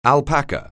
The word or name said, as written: alpaca